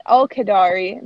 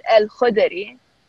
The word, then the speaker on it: Al-Khudri: So, like, mine is, like, I pronounce it, like, the Americanized version of it is Al-Khadari, but the real version of it is Al-Khudri. So, oh. It's, like, yeah, there's, like, they really use your throat for it.